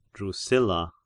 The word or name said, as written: Drusilla